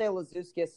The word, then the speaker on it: Lazauskas: Yeah, most people say Lazauskas, so that's the normal.